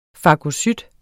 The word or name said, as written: fagocyt